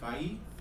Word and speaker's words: Feige: Kevin Feige. I don't know how to say his last name. – Feige. – Feige.